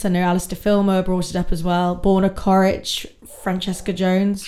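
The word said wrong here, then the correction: Ćorić: Chorich. – Oh, I've done it again. Chorich, we were told off about this and I'm still saying it wrong.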